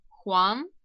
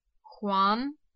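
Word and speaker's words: хуан (黄): Желтый цвет «хуан», «хуан», «хуан».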